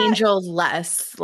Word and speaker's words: Angelus: Is that how you pronounce that? – Angelus, like less of an angel.